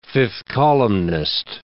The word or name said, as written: fifth columnist